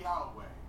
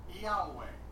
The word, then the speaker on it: Yahweh: I pronounce the name Yahweh. Josephus stated that there were 4 vowels on the high priest turban. Yud, He, Vav, He are pronounced as vowels in one breath, Yahweh, fluently, Yahweh. In Greek literature, Iota, Alpha, Omicron, Upsilon, Epsilon was used to approximate the name.